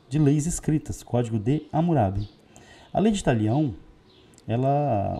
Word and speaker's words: Hammurabi: E da Babilônia, a gente destaca o seu principal governante, que é Hammurabi, ou Camurabi, né, na pronúncia da região.